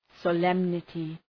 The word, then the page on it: solemnity: sə’lemnətı